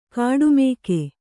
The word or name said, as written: ಕಾಡುಮಿಗ